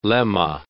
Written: lemma

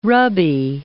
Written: Rabi

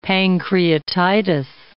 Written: pancreatitis

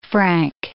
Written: frank

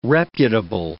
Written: reputable

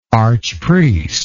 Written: archpriestship

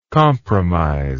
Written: compromissary

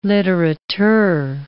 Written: litterateur

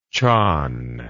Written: chan

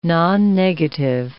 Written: nonnegative

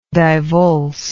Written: divulse

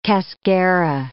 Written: cascara

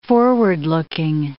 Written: forward-looking

